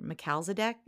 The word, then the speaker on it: Melchizedek: For me, it's just, I guess I'm probably the only ascended master I've called on is I'm going to say his name wrong. I say it. Melchizedek. – Yeah. – I think that's right.